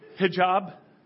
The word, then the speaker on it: hijab: You know, with the, what's it called, a habib? It's not a habib. Huh? – Head covering. – Head covering. Thank you for the English version. Hijab? Did I say that right?